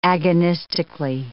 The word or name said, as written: agonistically